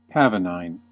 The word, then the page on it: pavonine: American English